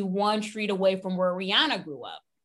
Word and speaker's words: Rihanna: Yes, Rihanna, because that's how you say it properly, yes. Right? Rihanna.